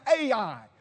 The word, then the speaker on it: Ai: That's how it's spelled, that's how it's pronounced, Ai.